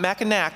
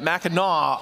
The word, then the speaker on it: Mackinac: Not Mackinac Island, right? Mackinac Island.